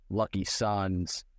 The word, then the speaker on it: LKYSUNZ: LKYSUNZ pronounced Lucky Sons.